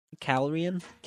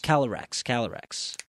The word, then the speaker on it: Calyrex: Now the, how do you say it? Calyrian? – Calyrex.